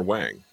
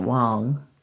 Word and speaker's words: Wang: And he is the doctor, Dr. W-A-N-G, Dr. Wang. What a delightful man. – Wong or Wang? – Well, I guess we who speak English would call it Wang, but I do believe they pronounce it Wong. – Sure, right. – He pronounces it Wong.